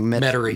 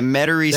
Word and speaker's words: Metairie: And then moving on, is it, I'm going to pronounce it wrong. – Metairie. – Yeah, Metairie Cemetery.